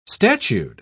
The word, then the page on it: statued: British English